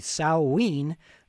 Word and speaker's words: Samhain: Yeah, from the beginning we heard Samhain, the correct pronunciation would be Samhain, with Unbridled, and Samhain is the ancient festival that Halloween most likely evolved from.